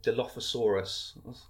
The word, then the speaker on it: Dilophosaurus: It's a Diloph- Dilophosaurus.